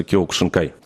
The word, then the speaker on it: кёкушинкай: Но вы сказали кёкушинкай, а мы говорим кёкусинкай. В чем разница произношения? Как правильно говорить? Потому что, ну, вот я слышал тоже по-разному кёкушинкай, кёкусинкай, кёкусинкай. В общем, как вот только не называют. – Сам вид боевого искусства называется кёкушинкай, карате.